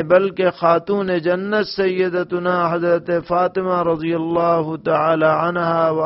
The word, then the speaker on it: فاطمہ: تو فاطمہ پا کے زیر کے ساتھ ہے اور اس کے معنی یہ ہے کہ وہ عورت جس سے اس کے بچے کا دودھ چھڑا دیا گیا ہو.